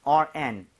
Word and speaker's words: intern: 중간에 R 있기 때문에 그냥 intern은 안 되겠죠? 혀가 뒤로 당겨져서 R 사운드를 낼 기회는 줘야 되는 거 아니에요?